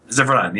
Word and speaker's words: Zevran: Zevran, yeah, that's how okay.